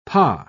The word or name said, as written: pas